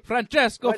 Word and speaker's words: Francesco: You have to say it like Francesco. Francesco. – You have to pinch the salt when you do it. – You got to roll that R a little bit, you know, Francesco. – Oh, he's not Spanish.